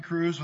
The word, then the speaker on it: Kroes: Hughes, yep, this is Preston Crews with Alaska State Parks. Last name is spelled K-R-O-E-S for the record.